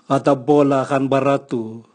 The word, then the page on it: gatabolu ganbaratu: Raga